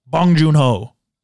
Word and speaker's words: Bong Joon-ho: Bong Joon-ho. – Yeah. I think that... Yeah, that's his name. – You said it right.